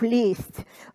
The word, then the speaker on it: лезть: «лезешь» мы делаем инфинитив «лезть», мы пишем з перед т э, произносим с, да,